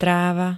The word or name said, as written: tráva